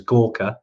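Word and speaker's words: Gurkha: Gorkha is quite a big popular one, which is where the Gurkhas are from, even though it's pronounced as Gorkha. I know it's been kind of spelt differently, but that's where all the Gurkhas originate from, which is quite cool.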